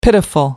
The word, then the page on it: pitiful: American English